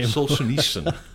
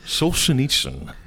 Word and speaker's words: Solzhenitsyn: You had this other quote from Alexander Solzhenitsyn. – If you could pronounce the last name. – Solzhenitsyn. Solzhenitsyn.